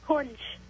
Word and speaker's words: Kunj: My name is Kunj, and the answer is lawn tennis. – What's your name again? – Kunj. – How do you spell it? – K-U-N-J.